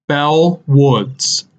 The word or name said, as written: Beall Woods